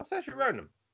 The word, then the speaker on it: Saoirse Ronan: My second option, and I'm not going to pronounce this right, Saoirse Ronan. – Oh, Saoirse Ronan. – Is that right? Oh. Is that how you pronounce it? – She was who we cast as Jean Grey.